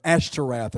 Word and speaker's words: Ashtoreth: Or Ashtoreth is probably the better pronunciation.